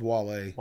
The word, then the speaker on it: Wale: W-A-L-E-S. – They spelled it like the country, yes. – Or like the singer. – Whales? – Whale, isn't there a guy named Whale? – Oh, it's Wale. – Wale, see? Yeah. Spelled like whales, though. – Yeah, it is. It's spelled W-A-L-E.